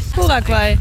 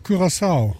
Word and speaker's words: Curaçao: Curaçao. Oder wie heißt es? – Curaçao. Curaçao. – Curaçao. – Curaçao? Doch, das war, glaube ich, richtig. Ne? Curaguay, oder? – Curaçao. – Curaçao. Curaçao. Keine Ahnung. – Curaçao.